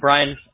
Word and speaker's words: Brian: Because I have a bunch of Brian's friends of mine, and they spell it all different. Is it with the B-R-Y-A-N or I-A-N? – I'm the I guy. – Ah, all right. – Brian. B-R-I-A-N.